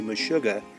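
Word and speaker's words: meshuga: There is a Yiddish word that is very similar, and it's pronounced the same way, Mashuga, and it means crazy.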